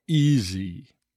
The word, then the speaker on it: easy: The letter S in easy is always pronounced with a Z sound. Easy, easy, easy.